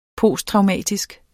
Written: posttraumatisk

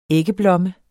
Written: æggeblomme

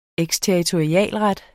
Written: eksterritorialret

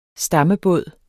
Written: stammebåd

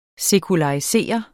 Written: SEKULARISERET

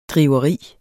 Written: driveri

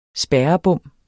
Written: spærrebom

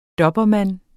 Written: dobermann

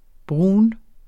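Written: brun

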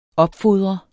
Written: opfodre

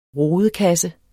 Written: rodekasse

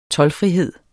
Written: toldfrihed

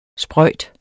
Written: sprøjt

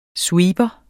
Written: sweeper

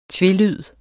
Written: tvelyd

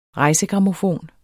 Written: rejsegrammofon

